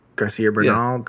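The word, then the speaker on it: García Bernal: And it stars, you know how to pronounce this guy's, Garcia Bernal, Garcia Bernal.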